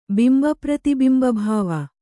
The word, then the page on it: ಬಿಂಬಪ್ರತಿಬಿಂಬಭಾವ: bimba prati bimba bhāva